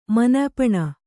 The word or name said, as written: ಮನ್ಯಪಣ